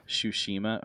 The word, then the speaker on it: Tsushima: Ghost of Tsushima, is that how you pronounce it? – Tsushima.